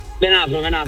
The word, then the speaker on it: Venafro: E allora, ho detto giusto il Venafro? – Venafro, sì, perché Venafro.